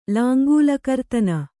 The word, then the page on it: ಲಾಂಗೂಲಕರ್ತನ: kannada